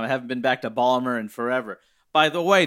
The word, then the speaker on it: Baltimore: Yeah, if you pronounced every letter, yes, that would be the pronunciation, but it's Balmer.